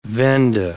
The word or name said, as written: Venda